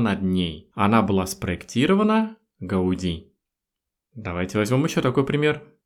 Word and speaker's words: Gaudí: В Испании ударение ставится на последний слог «Гауди». Не «Гауди», как мы привыкли это говорить, а именно «Гауди».